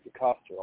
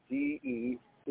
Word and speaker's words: Decoster: You just type in the name Decoster. I'll spell that for you, Decoster. Decoster, and you can find all my recordings there.